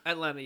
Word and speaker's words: Atlanna: as how do you say her name? Atlanna. Atlanna, yeah, yeah, Atlanna.